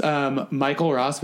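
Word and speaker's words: Mykle Raus: It's Michael Ross, but it's MYKLERAUS. – So it's spelled like... – It's basically spelled phonetically because I can never get my name in anything.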